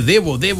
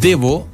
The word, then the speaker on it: Devo: Y me dice, ¿tienes a Divo? Sí, pues tenemos Divo. Anotamos Divo, pues, Claudia. Déjame anotar acá, Divo. Di. Con B larga, ¿cierto? Divo. No, pues se escribe Devo. Devo, ¿cierto? Eso es de Devolution.